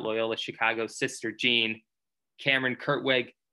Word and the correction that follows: Krutwig: I believe I said his name right. Krutwig. Krutwig. That's his name.